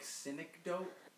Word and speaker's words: synecdoche: That movie, uh, New York Synecdoche. – Or, um, it's synecdoche. Synecdoche. Synecdoche. – I, I, I had to look up how to pronounce it, so don't worry.